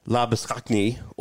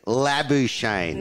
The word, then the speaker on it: Labuschagne: Well, it's funny because there was that debate whether it was Labuschagne or Labuschagne.